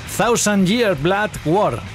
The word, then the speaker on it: Thousand Year Blood War: Thousand Year Blood War. ¿Lo he dicho bien? Perfectamente, te ha faltado decirlo en japonés.